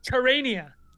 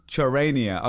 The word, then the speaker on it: Charania: Terrania. – Charania.